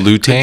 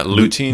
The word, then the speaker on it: lutein: And lutein? Good job. Yeah, lutein.